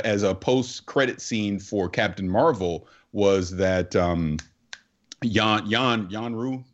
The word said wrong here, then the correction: Yon Rogg: that's how you say his name. But anyways, Jude Law's character, he was going to end up on Sakaar when Captain Marvel sent him off. It's Yon Rogg, thank you.